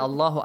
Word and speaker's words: Allah: Okay, but the reason of why do we recite it heavily is that, look, whenever you start reciting the Quran, and the first word is Allah, you have to pronounce it heavily.